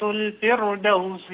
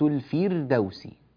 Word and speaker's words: الفردوس: رقق الراء أيها الفاضل. كانت لهم جنات الفردوس. – إن الذين آمنوا وعملوا الصالحات كانت لهم جنات الفردوس نزلا. – لا أنت تقول الفير، نريد الفير. فير انطق معي فقط الحرفين هذين فأرى فير فردوس. لا تقول الفردوس، قل فير فقط، يعني فير.